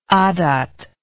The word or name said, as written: adat